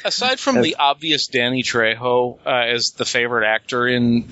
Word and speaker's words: Trejo: Danelle Harris, Tyler Mayne, Danny Trejo.